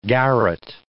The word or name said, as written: garret